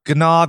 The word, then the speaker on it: gnaw: Gnaw, gnaw, gnaw, gnaw, gnaw. It's a silent G,